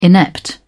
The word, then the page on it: inept: British English